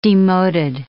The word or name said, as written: demoded